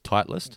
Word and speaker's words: Titleist: So how do you say it? – Titleist. – Just Titleist. Not no three syllables, just two syllables, Titleist? – Two syllables, Titleist. – So it's not Titleist. – Technically, it is Titleist, but more colloquially, you just run through it, Titleist. – Titleist, all right. Yeah. I learned how to say Titleist. Whether it's correct, I learned how to say it. Titleist? – Yeah, Titleist, run together. Titleist.